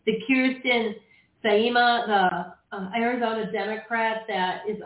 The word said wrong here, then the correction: Kyrsten Sinema: also a professor and has been for the last, 18 years at ASU, Kyrsten Sinema. – Sorry that I said her name wrong. – Movies, cinema, theater, Kyrsten Sinema.